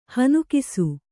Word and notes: ಹನುಕಿಸು: hanukisu

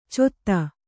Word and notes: ಚೊತ್ತ: cotta

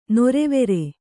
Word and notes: ನೊರೆವೆರೆ: norevere

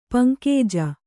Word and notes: ಪಂಕಜ: paŋkaja

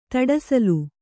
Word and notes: ಧೂಢಸೆಲ: dhūḍhasela

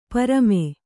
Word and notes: ಪ್ರೇಮಿ: prēmi

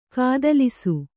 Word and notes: ಕತ್ತಲಿಸು: kattalisu; Kannada

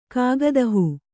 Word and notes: ಕಾಗದಹೂ: kāgada hū

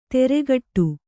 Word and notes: ತೆರೆಗಟ್ಟು: teregaṭṭu

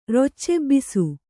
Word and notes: ರೊಚ್ಚೆಬ್ಬಿಸು: Kannada

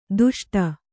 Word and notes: ದುಷ್ಟ: duṣṭa

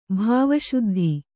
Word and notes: ಭಾವಶುದ್ಧಿ: bhāva śuddhi

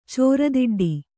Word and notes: ಚೋರದಿಡ್ಡಿ: kannada

